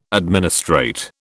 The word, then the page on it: administrate: British English